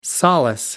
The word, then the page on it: solace: American English